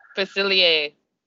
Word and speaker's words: Facilier: Oh, I can never say his name, the villain, Dr. Facilier.